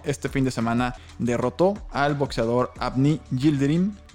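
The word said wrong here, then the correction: Yıldırım: espero lo haya pronunciado bien, Yildirim, Abni Yildirim.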